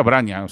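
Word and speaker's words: Vranje: Se pronuncia Vranja, ¿sabéis lo que es una Vranja en gallego? Pues eso, pues se pronuncia igual.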